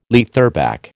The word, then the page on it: leatherback: American English